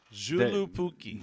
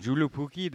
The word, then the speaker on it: Joulupukki: I mean, the one that I mentioned, and I can't pronounce this. I mean, it's Jolupuki. – Joulupukki. – Joulupukki, the Finnish Santa.